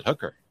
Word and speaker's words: Hooker: And I said, Hooker. And they said, can you spell that, please? And I said, just like it sounds. And I always say that, or I say just like the working women.